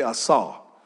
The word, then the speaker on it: asah: Somebody say after me, say asah. A-S-A-H. Now, that's not the way it's written in Hebrew, but that's the way it's written to be able to be pronounced.